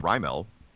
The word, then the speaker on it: Raimel: Everyone says Rymel. Our pronunciation guide for MLB says Rymel. Is it Rymel? That's how we've always said it. We've always said Rymel Tapia.